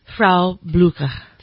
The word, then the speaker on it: Frau Blücher: Frau Blücher.